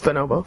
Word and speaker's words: Bonobo: I always want to pronounce that in, like, a Japanese accent, but it's just Bonobo. Bonobo.